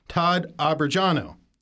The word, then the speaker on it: Todd Abrajano: Nuclear Infrastructure Council, Todd Abrajano.